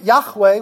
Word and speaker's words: Yahweh: But he knew that Yahweh was the proper way to pronounce the four-letter tetragrammaton.